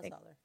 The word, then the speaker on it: Soller: But yes, for Kyle Soller, you are correct.